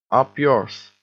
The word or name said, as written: up yours